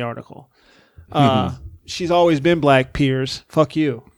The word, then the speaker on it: Piers: Piers. – Piers.